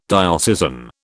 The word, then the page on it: diocesan: British English